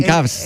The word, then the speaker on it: Cavs: No, está bien, Cavs.